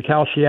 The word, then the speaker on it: Kalshi: How do you pronounce, how do you spell that? I'm trying to look this up. K-A-L-S-H-I. – Thank you. – Kind of like the cereal.